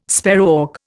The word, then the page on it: sparrowhawk: Indian